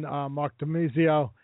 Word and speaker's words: Mark Domizio: And now I welcome in Mark Domizio, guitarist, and CP Love, the vocalist from For Real.